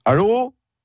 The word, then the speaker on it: allô: Bon, pour allô, c'est simple, faut juste mettre l'accent tonique sur le O. Ça donne allô ?